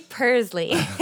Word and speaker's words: Pursley: It's Carly Pursley. Like a cat.